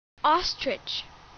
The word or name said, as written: ostrich